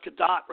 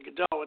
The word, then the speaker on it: Gadot: I thought Wonder Gadot, and I've been corrected now that it is pronounced Gadot rather than Godot. It's not French. It's Israeli.